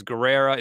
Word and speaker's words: Guerrera: First of all, my last name is Guerrera. It's spelled a little weird. A lot of people mispronounce it.